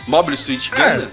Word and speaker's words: Mobile Suit Gundam: Mobile Suit Gundam. Falei certo, gente? Mobile Suit é, Gundam? – É. Tá certo.